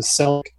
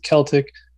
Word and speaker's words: Celtic: It was based on my research, it was a Celtic, I believe they pronounce it Celtic, but I watch too much basketball, so I call it Celtic.